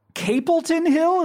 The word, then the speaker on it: Capleton Hill: The new record is called From Capleton Hill. Is that how you pronounce Correct. – It? – Correct. That's how you do it.